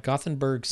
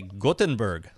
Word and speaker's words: Gothenburg: So we have an email from Christian in Gothenburg, Sweden. – I'm going to say Gothenburg, Sweden. – Gothenburg? – I don't think you pronounce TH like TH in English.